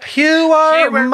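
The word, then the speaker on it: Heuermann: Am I saying that right? – Huerman.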